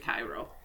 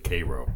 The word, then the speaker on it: Cairo: It's actually Cairo. – This is Illinois. We pronounce it Cairo.